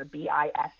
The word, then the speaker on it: Bissell: And so the work of famous researcher, cancer researcher Mina Bissell, who has a fantastic TED Talk, her last name is spelled like the vacuum cleaner, Bissell.